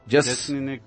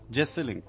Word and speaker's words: Jeselnik: Jeselnik. – How do you pronounce that? – Jeselnik.